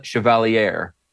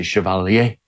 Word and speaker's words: Chevalier: Lost the primary to Mamdani-backed Daria Lisa Avila Chevalier, I believe is the actual pronunciation, even though I want to say Chevalier. – Isn't Chevalier. – Is it Chevalier? It's Chevalier? Like the Chevy Cavalier mashed into one word.